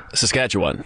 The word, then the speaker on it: Saskatchewan: Saskatchewan. – Yeah, Saskatchewan.